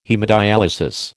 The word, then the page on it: haemodyalisis: American English